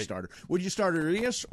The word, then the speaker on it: Urias: Urias.